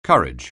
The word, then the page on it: courage: anglais